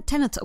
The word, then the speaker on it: Tenet: Ee, filmin adı Ten, Tenet okunuyor Tenet.